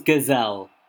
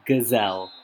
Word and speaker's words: Ghezzal: It was Ghezzal. They were calling him Ghezzal. Ghezzal the Ghezzal.